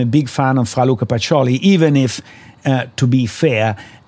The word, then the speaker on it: Luca Pacioli: I couldn't help but notice that the father of accounting and bookkeeping, and I'll pronounce this badly, I'm afraid, Luca Pacioli? – Yeah, you pronounce it very well, yes.